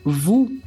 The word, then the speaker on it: vous: E uma pequena observação sobre a pronúncia é que escrevemos vu com V, O, U, S, mas a gente não fala o S na pronúncia. A gente apenas fala VU.